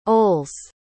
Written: Oles

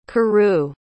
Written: kurou

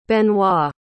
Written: Benoît

